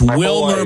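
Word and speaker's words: Wielmer: But okay, so it's Wilmer, but it's spelled with more syllables.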